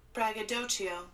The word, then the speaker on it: braggadocio: Braggadocio. – Oh, I'm saying it wrong. Oh, braggadocio. – Okay, there we go.